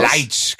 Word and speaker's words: Leitsch: wie wird er richtig ausgesprochen? – Leitsch fällt aus. – Leitsch, genau.